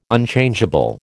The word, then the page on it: Unchangeable: American English